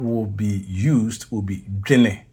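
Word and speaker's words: dwene: If I spell that in English, it would be D W E N E. D W E N E. And it's pronounced jine.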